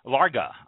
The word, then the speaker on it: Larga: We do have someone else tonight with us, Andrea from Casa Largo, Larga, I believe it's pronounced, Casa Larga Vineyards.